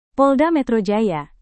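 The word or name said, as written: polsek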